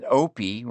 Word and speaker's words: Opie: And they all figured out that Opie rhymes with dopey, mopey, and soapy.